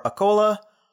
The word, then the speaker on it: Akola: It's close to a place called Nanded or Akola.